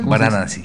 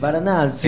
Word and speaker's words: Varanasi: ¿Esta ciudad cómo es? ¿Se pronuncia? – Varanasi. – Varanasi. – Está subida al Facebook ahora, sí. – Es Varanasi. En India.